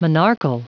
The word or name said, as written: monarchal